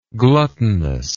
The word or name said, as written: gluttonousness